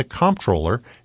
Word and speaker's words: Comptroller: For the first time in 20 years, New York State's Comptroller Tom DiNapoli is facing challengers in this year's primary on June 23rd. WAER's Bryson Pace takes a closer look at what the Comptroller actually does.